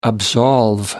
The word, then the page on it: absolve: American English